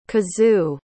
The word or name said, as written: kazou